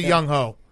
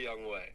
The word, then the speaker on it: Younghoe: Hey, how do you say the kicker from Atlanta's name? – Young Wei. – Okay, I say Younghoe. So agree to disagree. – Yeah, it's definitely Young Wei.